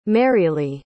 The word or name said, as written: marylee